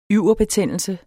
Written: yverbetændelse

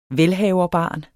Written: velhaverbarn

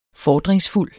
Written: FORDRINGSFULDT

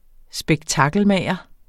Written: spektakelmager

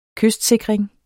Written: kystsikring

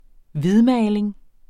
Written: hvidmaling

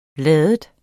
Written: lade

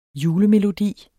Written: julemelodi

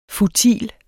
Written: futil

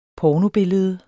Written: pornobillede

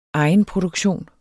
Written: egenproduktion

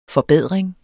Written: FORBEDRINGERS

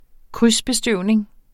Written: krydsbestøvning

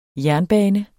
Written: JERNBANERNES

